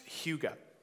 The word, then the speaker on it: hygge: And one of those words was a Danish word that is pronounced hygge.